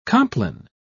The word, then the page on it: complin: British English